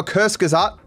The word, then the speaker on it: Kurzgesagt: How Kurzgesagt, saying it correctly now, at least closer than I was before, cooks propaganda for billionaires.